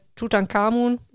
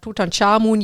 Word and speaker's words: Tutanchamun: Es ist ein neues Buch zu Tutankhamun oder Tutanchamun, je nachdem, wie man ihn aussprechen mag, rausgekommen, das auch noch bei mir liegt und das ich sehr gerne lesen möchte.